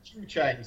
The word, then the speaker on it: Zhucheng: Zhucheng. So, yeah, so it's Chinese pronunciation of English characters. So, Z-H-U is kind of Zhu or Jew.